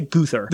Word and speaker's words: Gruthar: G-R-U-T-H-A-R. Guthar. I looked up how to pronounce it, and it was like Guthar, and I was like, that's not correct. So I'm going to say Guthar.